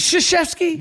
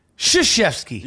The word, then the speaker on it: Krzyzewski: You call it Kr. It's Sh. – Is it Krzyzewski? – Yeah. The K is silent. – Says who? – English law, right? – Krzyzewski. – Yeah. – Krzyzewski.